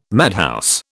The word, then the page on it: madhouse: British English